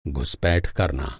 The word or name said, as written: घुस-पैठ